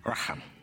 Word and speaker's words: raham: Well, the Hebrew translation of the word mercy is raham, It's literally pronounced that, Raham.